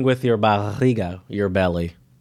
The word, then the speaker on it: barriga: I also have a Portuguese one, and it's empurrar com a barriga. How would you say that? B-A-R-R-I-G-A.